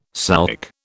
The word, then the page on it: souk: British English